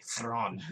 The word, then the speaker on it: Thrawn: You're doing it in a very cool way, Thrawn. Like Thrawn.